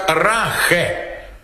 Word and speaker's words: Rache: R-A-C-H-E по-немецки читается как Рахе, что означает месть.